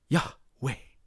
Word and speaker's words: Yahweh: The name of God is spelled like this YHWH, Yahweh. And you pronounce it like this Yahweh. And it's specifically written like that because it's breathing. It's Yahweh. That's how you say God's name.